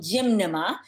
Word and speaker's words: Gymnema: It's called Gymnema. It's spelled E-Y-M-N. N-E-M-A. Did I say that? Did I spell that right? Gym Nema. So gym, like I'm going to the gym. – Yeah. – Gym, N-E-M-A, Gym Nema.